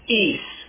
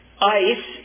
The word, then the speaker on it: eis: The Greek word that's translated for in Matthew 3:11 should be pronounced eis. E-I-S is eis. Ice is the way you'll often hear it translated. Ace is the way most people among us will utter the word, vocalize it. Eis is what the Greek native tells me is how they would pronounce it.